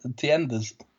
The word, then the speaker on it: tiendas: How do you say it? Tiendas. – Las tiendas. Yeah, las tiendas.